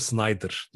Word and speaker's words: Snyder: Ama şimdi diyor Snyder diyor okunuyor bu arada Queen Snyder.